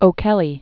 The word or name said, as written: O'Kelly